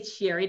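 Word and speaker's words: Sherri: It's Sherry.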